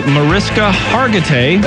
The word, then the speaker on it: Mariska Hargitay: Mariska Hargitay.